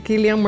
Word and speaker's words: Cillian: Tengo, que el nombre se pronuncia con K en vez de C. – No la tengo. No tengo a Cillian Murphy.